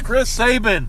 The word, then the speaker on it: Chris Sabin: And so after the show, they became a number one contenders match between Chris Sabian, James Storm versus Private Party. – His name's Chris Sabin. – Chris Sabin, I'm so sorry, you threw me off with the Sabin thing.